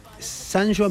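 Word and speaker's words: Sanyo: En Argentina se pronuncia Sanyó. – Sanyó. – No Sanyó.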